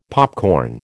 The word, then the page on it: popcorn: American English